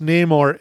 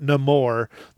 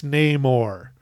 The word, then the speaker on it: Namor: And it is pronounced Namor. It is not Namor. That's some Disney bullshit they made up. It's Namor.